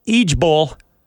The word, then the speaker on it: IJBOL: And apparently the kids are pronouncing it EJBOL.